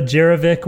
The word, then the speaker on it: Jirovec: Melissa Jirovec, welcome to the Show.